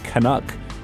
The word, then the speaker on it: Canuck: Thanks also to Judy for writing in to correct my pronunciation of Canuck, which rhymes with luck or muck or another word that she pointed out.